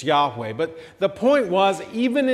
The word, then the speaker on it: Yahweh: We think it was probably pronounced Yahweh, and it means I am.